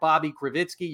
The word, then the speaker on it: Bobby Krivitsky: Another way to check this out, you can follow me on Twitter at Bobby Krivitsky.